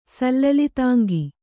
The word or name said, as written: ಸಲ್ಲಲಿತಾಂಗ